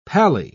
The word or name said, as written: pally